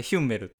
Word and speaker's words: Hummel: ハモって読むの。あれ、ヒュンメルって読むの、日本語。 – Oh, really? Hummel.